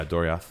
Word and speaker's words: Doriath: And their kingdom was known as, I forget how to pronounce it, Doriath? – Yeah, Doriath. Doriath. That's how I'm saying it.